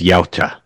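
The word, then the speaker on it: Yautja: What I've heard, it's Yalta.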